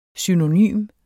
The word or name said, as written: synonym